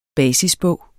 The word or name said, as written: basisbog